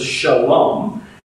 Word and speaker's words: shalom: At one level, "peace be with you," or what would be pronounced as "shalom," is the standard everyday greeting for Jewish people.